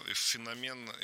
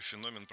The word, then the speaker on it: феномен: Ты же наверняка в своей программе разбирал вот этот феномен, или феномен, правильно говорить, человека, который играет вторую роль.